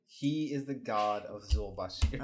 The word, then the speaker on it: Zul-Bha-Sair: Yeah, I keep wanting to put a shh on it, but I think it's Zulbasir. Zulbasir. There's an H in there. – Well, I think that H is just for, it's a silent H.